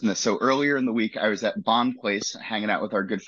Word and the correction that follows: Bond Place: Pronounce it Bon Place.